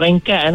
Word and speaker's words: rengkat: Seterusnya, Ringkat. Ejaan dia R-E-N-G-K-A-T. Atau dia ada satu lagi, dia tak sebut ring. Mm-mm. Dia sebut ring. Ringkat. Ejaan dia R-I-N-G-K-A-T.